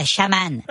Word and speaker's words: chaman: Ça se prononce chaman.